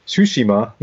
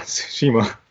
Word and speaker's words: Tsushima: It's Ghost of Tsushima, not Tsushima.